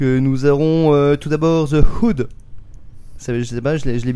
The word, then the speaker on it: Hood: Alors on n'a pas nos experts en anglais, donc on nous a dit aspire le H. C'est tout ce qu'on m'a dit. Moi, après, The Hood. Hood. – Voilà, j'essaierai de le prononcer comme ça, un peu comme coude, mais avec un C en moins.